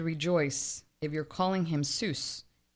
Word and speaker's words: Seuss: It's not Dr. Seuss. It's Dr. Seuss. That one's mind-blowing. It really, really is. It rhymes with voice.